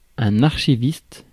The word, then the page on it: archiviste: /aʁ.ʃi.vist/; France